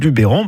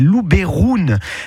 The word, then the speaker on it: Luberon: En fait, en provençal, le E se prononce E. Donc, si vous dites Luberon, bah, c'est presque comme la prononciation d'origine de cette région. Je m'explique. La prononciation d'origine en provençal, c'est Luberoun.